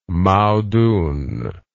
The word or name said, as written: Mao Dun